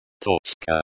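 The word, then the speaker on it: Toczka (точка): Pronunciation. Toczka. Toczka. Stress on the first syllable.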